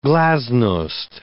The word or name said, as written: glasnost